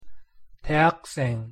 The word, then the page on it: 대학생: Korean